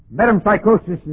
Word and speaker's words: metempsychosis: Metempsychosis is spelled M-E-T-E-M, which has the idea of carrying over the soul. M-E-T-E-M, and then psychosis.